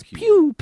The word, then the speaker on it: Pugh: I think the uh, G is silent. Yeah. It's pew pew pew pew pew.